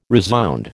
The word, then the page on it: resound: American English